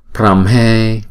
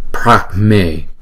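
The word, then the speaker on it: Brahme: Chúng ta có thể phát âm là Pramhe, Pramhe, Pramhe.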